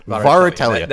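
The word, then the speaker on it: Värähtelijä: So Oransi Pazuzu Varatelia is, I believe, how you pronounce it. Varatelia. – That looks right.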